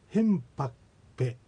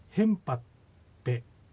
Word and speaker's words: ヘンパクペ: それに対してヘンパクペ、これはあの先ほどのそのヘンパともそうなんですが、ゆっくり丁寧に発音すればヘンパクペと K の音がきっちり発音されますが、普通の発音ではこの石狩川筋ではヘンパペというような、発音になります。